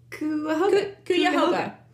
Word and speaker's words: Cuyahoga: See, say that. – Cuyahoga. – Cuyahoga. – Yeah. – Sure. – It's spelled like that. C-U-Y-Hoga.